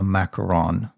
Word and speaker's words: macaron: but the correct pronunciation of your confections is yes. – A macaron.